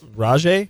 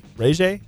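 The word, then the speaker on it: RayJ: His name actually, it's R-A-Y-J. R-A-Y capital J. – Like, that's his name? – That's his first name. – So, Rajay? Ray J? – No, it's literally pronounced Ray J.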